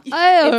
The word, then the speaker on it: Ione: Ione, if you don't know, Ione. – It's spelled literally I-O-N-E, so it's like kind of phonetic, but it's also kind of tricky.